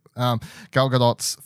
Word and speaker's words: Gadot: It's actually, I'll say the T apparently. I've been saying it wrong for years. Gal Gadot's first film role as well is this movie.